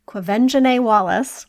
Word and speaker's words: Quvenzhané: She's played by, and I'm going to say her name right because I did look it up, Quvenzhané Wallace.